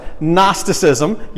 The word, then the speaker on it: Gnosticism: Gnosticism. The G is silent. Gnosticism. Yes, that's it.